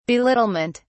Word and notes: belittlement: British English

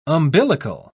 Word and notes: umbilical: British English

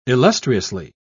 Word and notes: illustriously: British English

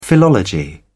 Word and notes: philology: British English

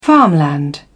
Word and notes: farmland: British English

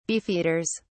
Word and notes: beefeaters: British English

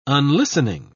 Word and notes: unlistening: British English

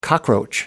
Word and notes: cockroach: American English

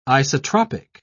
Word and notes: isotropic: British English

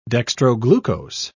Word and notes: dextroglucose: British English

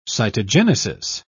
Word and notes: cytogenesis: British English

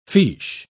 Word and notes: fiche: British English

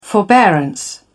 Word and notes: forbearance: British English

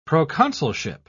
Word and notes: proconsulship: British English

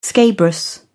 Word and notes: scabrous: British English

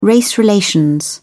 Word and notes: race relations: British English

